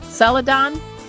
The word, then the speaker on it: Celadon: Now, the print version is it pronounced Celadon? – Yes.